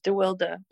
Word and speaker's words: DeWilde: You know, I had a boyfriend named Brandon DeWilde, spelled the same way. But you say DeWild, right? – Wild. Well, actually, in Dutch, when I'm in Holland, that's how they pronounce it, is DeWilde.